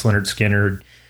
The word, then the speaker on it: Lynyrd Skynyrd: So a lot of Lynyrd Skynyrd, like pronounced Lynyrd Skynyrd, Second Helping, you know, Street Survivors.